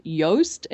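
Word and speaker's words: Joost: Now, there's one called WordPress SEO, and it's by a guy named Yoast. It's spelled differently though. It's uh, I think it's J O O S T.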